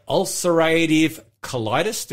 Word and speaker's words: ulcerative colitis: So, Leah, you have ulcerative colitis.